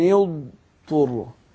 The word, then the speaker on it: اضطر: فلما اقول ايه فمن يضطر يبقى انا احقق المخرج الضاد لان مفيش ادغام هنا. انا لو نطقتها على طول كده هيظن ان في ادغام لكن لا مفيش ادغام. يبقى انا فمن يضطر وصوت الضاد المخرج بتاعه قوي واخد بالك فباين صوت الضاد.